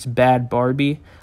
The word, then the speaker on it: Bhad Bhabie: But ooh, well, Bhad Bhabie, which actually isn't even how you say her name, it's Bad Barbie, and I've been saying it wrong the last few years, and I feel like a complete idiot.